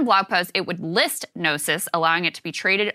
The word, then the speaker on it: Gnosis: They did this report that found over 6 days last August, one crypto wallet amassed a stake of $360,000 worth of, how do you say this, Sagar? G-N-O-S-I-S, Gnosis coins? – Yeah, that's Gnosis.